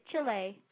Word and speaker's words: Chile: You think I say Chile funny? I thought that's how you said it. That is how you say it. Not Chile. – No, that's right. How you say it. Chile. You're right.